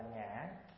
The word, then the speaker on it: ngã: Khi mình nói ngôn từ, mình nói chuyện thì mình kêu ngọ, nhưng khi đi vô chữ nghĩa thì gọi là ngã. Giống như người quản ta nói nị ngọ, ngọ ái nị, nị ái ngọ đó. Chữ ngọ là khi văn nói, khi đi vào chữ thì đọc là ngã.